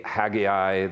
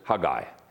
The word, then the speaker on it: Haggai: By the way, English pronunciation can be Haggai. The Hebrew pronunciation is Haggai. Haggai. So you might find me alternating between those two pronunciations. Either one really is appropriate.